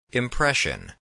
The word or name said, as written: impression